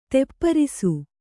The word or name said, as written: ತೆಪ್ಪರಿಸು